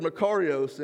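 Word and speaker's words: makarios (μακάριος): I mispronounced it last week, so I apologize. It is the Greek word makarios,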